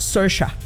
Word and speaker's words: Saoirse: El de ella, de hecho, se escribe Saoirse. Saoirse Ronan, así se escribe, pero se pronuncia Saoirse. Algo así lo pronuncia ella.